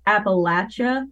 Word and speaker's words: Appalachia: Moesha, it's pronounced Appalachia. Like, I'm going to throw an Appalachia and not Appalachia.